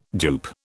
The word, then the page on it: dupe: British English